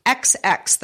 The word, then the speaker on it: ExEx: Both these surgeons were performing spinal surgeries and both used software from a company called XX, that is spelled E-X-E-X.